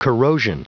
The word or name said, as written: corrosion